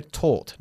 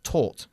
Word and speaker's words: taught: El pasado de este verbo se pronuncia taught, no taught. – Ok, taught. – ¿Vale? Taught. Casi como tortilla. Vale, cambiamos.